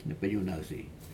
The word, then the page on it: nepig unausei: Aneityum